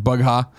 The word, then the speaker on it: Bugha: Booga. I always say his name wrong, I think. Bugha.